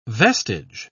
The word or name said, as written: vestige